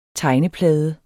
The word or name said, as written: tegneplade